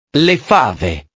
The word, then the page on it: le fave: italiano